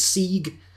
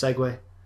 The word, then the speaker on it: segue: I thought that was Sieg, and I thought that segue was the thing, was the motorized. I thought that was what that was. Not that they were both pronounced segue.